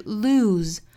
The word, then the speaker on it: lose: 最后还要注意这个 lose 这个词， se 对应的是浊辅音的 z 的发音，所以这个词是 lose 不要发成了露，不是我们汉语露天的那个露那个词哈，